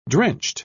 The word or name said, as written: drenched